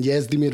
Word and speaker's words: Jezdimir: It is, the J is a Y, basically. So it's Jezda and Jezdimir Vasilievich.